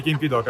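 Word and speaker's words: Kim Ki-duk: voce alta, permettimi di dire che è il Querel de Brest di Kim Piduk. Kim Pidok, anzi, Kim Pidok è la pronuncia.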